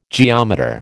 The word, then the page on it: geometer: American English